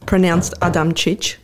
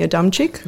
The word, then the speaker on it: Adamčić: My last name used to be, well, it's pronounced Adamčić. – Adamčić. – Yeah, but it's spelled A-D-A-M-C-I-C, so everyone used to call me Adamčić.